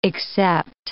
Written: accept

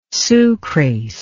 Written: sucrase

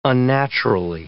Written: unnaturally

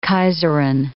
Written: kaiserin

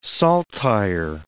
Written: saltire

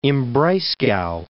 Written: Freiburg im Breisgau

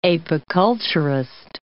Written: apiculturist

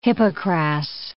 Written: hippocras

